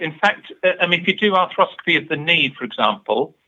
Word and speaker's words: arthroscopy: Now, I noticed that you do arthroscopy. Is that right? – I do, yes. – First of all, have I pronounced that correctly? – Arthroscopy. Yes, that's it.